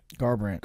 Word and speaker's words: Garbrandt: But I said Gambrant, but how do you pronounce his last name? – Garbrandt. – Garbrandt, yeah.